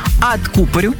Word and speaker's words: откупорить: Большой соблазн поставить ударение на третий слог, но во всех производных этого слова мы будем говорить «откупорю», «откупорит», «откупорим», ну и так далее.